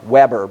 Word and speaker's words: Weber: W-E-B-E-R, Weber, but pronounced Weber, a German sociologist at the beginning of the 20th century.